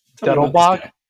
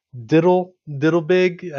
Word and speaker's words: Dettelbach: Was it Stephen Dettelbach? – Well, you said it right. President Biden couldn't say it.